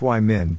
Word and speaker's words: Wyman: His professor, a very talented artist by the name of Bill Wyman, pronounced Wyman but not the Rolling Stones bassist, was my dad's watercolor professor at that time.